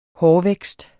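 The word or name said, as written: HÅRVÆKSTEN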